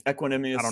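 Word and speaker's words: Equanimeous: Equanimous St. Brown.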